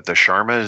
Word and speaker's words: shawarma: The shawarma is there. Am I pronouncing that right? Shawarma. – There you go.